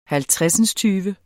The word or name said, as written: halvtredsindstyve